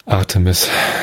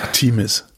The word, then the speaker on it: Artemis: Artemis. Artemis. Wäre ich im Leben nicht drauf gekommen, das Ding so zu nennen. Einfach die Betonung auf die zweite Silbe.